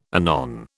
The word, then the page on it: anon: British English